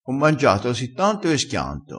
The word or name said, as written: schianta